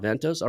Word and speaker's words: Advantos: He's since moved from the corporate world into the world of entrepreneurship through acquisition, being a co-founding partner of Advantos. Did I say that right? – Mm-hmm. – Advantos, all right.